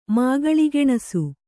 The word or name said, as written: ಮಾಗಳಿಗೆಣಸು